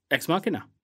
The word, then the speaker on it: Ex Machina: Ex Machina.